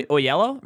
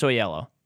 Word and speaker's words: Oyelowo: How do you say that guy? – Oyelowo, right? – Oyelowo. – I think it's Oyelowo.